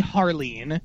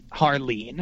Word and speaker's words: Harline: So what did Lee Harleen, is that how it's pronounced? – Yes, Lee Harleen, yes. Strangely spelled H-A-R-L-I-N-E, so you'd think it was Harline, but it's Harleen. Yes. I don't know why.